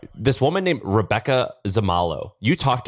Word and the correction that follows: Zamarolo: Yeah, I think you butchered her last name. Zamarolo, I think. – Zamarolo, sorry.